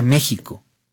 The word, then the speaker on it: México: México se pronuncia México. Y la historia de por qué se pronuncia México es de verdad muy bella, porque tiene que ver con la evolución del sh.